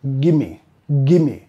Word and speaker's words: gimme: Así que estamos más como vinculando estas dos. Gimme, gimme. Don't gimme that.